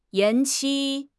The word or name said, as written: 延期